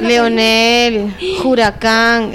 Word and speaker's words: Leonel Juracán: Hay un poquito de todo, selección de Kunti y de nuestro compañero Leonardo Huracán. Ya me corrigieron, ¿cómo se dice? – Leonel Huracán. Es que Leonardo te sale en el autocorrector.